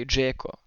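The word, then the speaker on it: Džeko: Ja, alltså jag kan ju typ störa, alltså med, som, med bosniskt påbrå så folk som säger Dzeko det är också en sån här sak. Liksom. Det är ju Dzeko som, som det ska uttalas.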